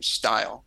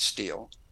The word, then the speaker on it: Steil: that pronounced the name Steil. We pronounce it Steil.